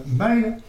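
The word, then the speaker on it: mile: À quel maille terrestre se prononce maille et s'écrit M-I-L-E, M-S-O-N.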